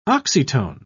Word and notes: oxytone: British English

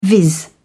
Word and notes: viz: British English

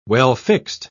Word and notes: well-fixed: British English

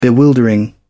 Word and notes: bewildering: British English

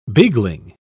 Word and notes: beagling: British English

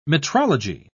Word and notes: metrology: British English

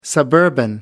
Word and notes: suburban: American English

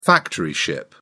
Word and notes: factory ship: British English

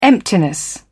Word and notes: emptiness: British English

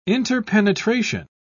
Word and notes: interpenetration: British English

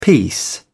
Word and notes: peace: British English

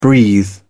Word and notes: breathe: British English

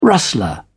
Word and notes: rustler: British English

